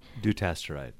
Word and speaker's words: dutasteride: We stopped the durasteride. Am I saying that right? – Dutasteride.